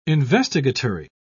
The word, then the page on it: investigatory: British English